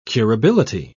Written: curability

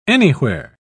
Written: anywhere

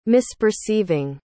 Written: misperceiving

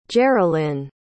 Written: jerilyn

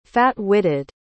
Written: fat-witted

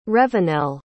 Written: revenel

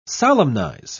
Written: solemnize

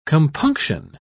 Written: compunction

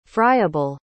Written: fryable

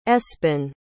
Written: espen

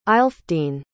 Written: aelfdene

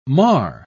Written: mar

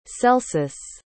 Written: celsus

